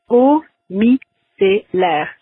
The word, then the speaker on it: eau micellaire: It's eau micellaire. So the O sound in French is kind of like the O in a word like cold. It's eau, eau.